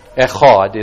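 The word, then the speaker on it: Echad (אחד): Echad is how that's pronounced.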